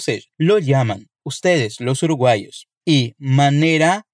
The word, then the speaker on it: llaman: E na sequência, para confirmar se é tudo mega diferente mesmo, ela pergunta o seguinte: Todos lo llaman de maneira distinta assim? Percebeu como pronunciei o verbo llamar com aquele i, porque quem está falando é a Lisette, que é mexicana.